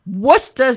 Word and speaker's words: Worcester: W-O-O-S-T-E-R. It is not Worcestershire. It's Worcester. – Worcester sauce.